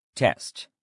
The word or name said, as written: test